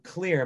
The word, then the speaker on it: Xlear: And basically, it's called Clear, but it's spelled an X. It's like X-L-E-A-R.